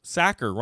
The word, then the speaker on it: Sachar: Sacker rhymes with cracker.